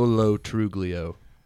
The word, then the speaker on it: Lo Truglio: British Joe Latruglio? Whatever his name is? – Truglio. Joe Lo Truglio. That's how it's spelled.